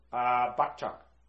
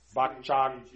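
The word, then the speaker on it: Bakchak (བག་ཆགས): B-A-G-C-H-A-K. – Something. – Bakchak. Kak. I mean, the problem is that in Tibetan, a lot of these Tibetan syllables, that there is, often they're in between like G and K. And so, you know, how do you spell it? It depends a little bit on, so it's difficult.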